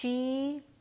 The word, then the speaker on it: Sidhe: Okay, so so the she, Sidhe, is how this is spelled.